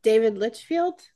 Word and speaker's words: Litchfield: David Litchfield? – Yes. – I might be saying his name wrong too. – That sounds right.